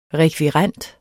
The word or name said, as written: rekvirent